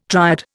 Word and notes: triad: Indian

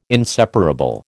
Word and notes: inseparable: American English

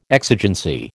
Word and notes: exigency: American English

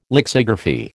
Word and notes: lexigraphy: American English

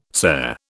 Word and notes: cere: British English

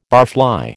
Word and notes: barfly: American English

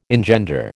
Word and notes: engender: American English

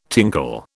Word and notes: tinkle: British English

